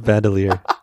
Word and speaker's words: bandolier: Jesse, say that word. – Bandoiler? – How do you say it? It's a bandolier.